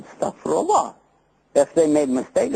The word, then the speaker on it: Astaghfirullah: Astaghfirullah. Astaghfirullah.